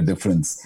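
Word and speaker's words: dfrens: It's D-F-R-E-N-S, but pronounced as difference, as in making a difference.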